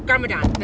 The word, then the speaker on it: Garmadon: His name is, how do you say, Garmagan? Let's see how we say it here. But he Garmadon. That's it, Garmadon.